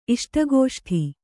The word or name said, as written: ಇಷ್ಟಗೋಷ್ಠಿ